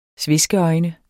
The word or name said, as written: sveskeøjne